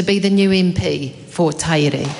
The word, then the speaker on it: Tairi: It is frequently mispronounced Tairi, which is an island off the coast of Scotland. Many of us whakapapa to Scotland down our way, so it's easy to see how this happened. However, I can assure this House that Marcus Lush was correct in his pronunciation. Our place is Tairi, which speaks to our awa, the fourth longest river in New Zealand.